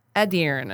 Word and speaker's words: Edirne: Edirne. I said it right, because I stressed the E.